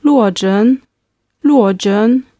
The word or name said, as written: luozhen